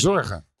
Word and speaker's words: Zorge: Uh, I know I always say your name wrong, but go with me, Zorge. Zor- Zorge. – Zorge. Zorge. – Okay. – It's the G.